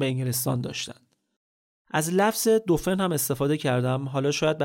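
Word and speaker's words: dauphin: بهش میگفتن دوفن که تلفظ فرانسوی دلفین هم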